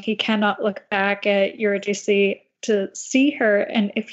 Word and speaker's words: Eurydice: but Eurydice and Orpheus and Eurydice.